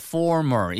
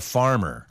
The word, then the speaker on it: former: F-O-R-M-E-R. 그래서 former. 이전은 yeah. 약간 공식적인 표현이죠. – Well, you've got to have good pronunciation here. This guy is not a farmer. Not a farmer, he's a former.